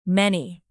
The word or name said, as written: many